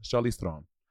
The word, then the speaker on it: Charlize Theron: She's like, it's actually pronounced Charlize Theron. But everyone says Theron.